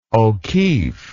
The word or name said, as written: o'keeffe